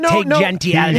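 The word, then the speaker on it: Jeanty: The running back, how do you say his name, Gentry? – Genty.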